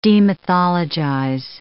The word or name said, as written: demythologize